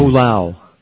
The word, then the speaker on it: Fualaau: Vili Fulau, how do you pronounce this name? – Fulau. – Is that it? – That's what they kept saying during the trial. – F-U-A-L-A-A-U. God, there's a lot of letters in there, eh? – L.